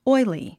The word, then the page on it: oily: ɔ́ili; オイリィ